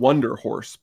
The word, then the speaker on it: Wunderhorse: They're called Wonder Horse, but it's spelled WUNDERHORSE. And Okay.